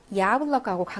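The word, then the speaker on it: 야블럭하고 (яблоко): 야블럭하고 강세가 여기에 있어요. 그러니까 자기 소리 야 발음 납니다.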